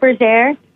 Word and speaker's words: Berger: I say it Berger, but I've heard all sorts of ways, so it's totally, you know, any way is fine.